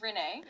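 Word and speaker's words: Rene: And then if you look really closely at the logo, It actually, my name is Renee, right? So it's R-E-N-E.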